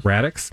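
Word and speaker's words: Raddix: Radix. Rhymes with Maddox.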